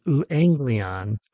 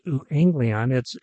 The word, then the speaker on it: euangelion: The Greek word for gospel is euangelion, euangelion, pronounced euangelion.